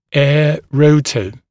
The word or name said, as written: air rotor